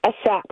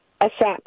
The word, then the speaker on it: Ashap: Ashap. – Say it again. – Ashap. A-S-H-A-P. – Ashap. Navi Ashap? – Ashap, yeah. That's actually the correct pronunciation, Gavin.